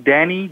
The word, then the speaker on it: Danny: So that's my name, Danny, D-A-N-N-Y.